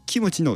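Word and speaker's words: キ ム チ (김치): 例えば例を出しますと、まあ、キムチというですね、まあ、単語があったとしてですね、そうですね、まあ、そのキムチのムーがですね、まあ、日本語ですと MU じゃないですか。 – キム、うんうんうん、なんですけども M の発音だけです。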